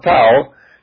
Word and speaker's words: tau: It developed into an X shape, and then in Greek it is pronounced Tau and took the form of our capital T.